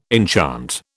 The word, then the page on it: enchant: British English